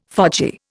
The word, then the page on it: fogy: Indian